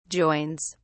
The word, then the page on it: joins: British English